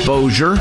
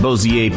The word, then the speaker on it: Bossier: I think it's called Bossier. If I mispronounced it, and it's Bossier City and Bossier Parish. Call Walter Sterling right now and set him straight and set me straight. But I believe it is Bossier. I believe that's how it's pronounced.